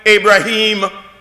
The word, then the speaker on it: Ibrahim: They call him Ibrahim. This is another Arabic pronunciation.